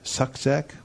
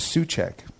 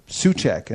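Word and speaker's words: Suczek: But if someone comes out and they go, the way it's pronounced is Suchek, it's a Czech name, and they say Suchek, and I go, well, they must know me.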